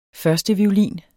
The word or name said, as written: førsteviolin